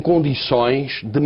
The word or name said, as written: condicoes